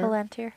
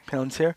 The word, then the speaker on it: Palantir: I will say it wrong. The Palantir. – Palantir. – Palantir. Yes, which I was so excited when I saw that.